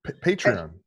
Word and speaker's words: Patreon: And I also have, I can never pronounce this fucking word, a Patreon? Patreon? – Patreon. – Patreon. I got one of those Johns. – Patreon. Say it with me.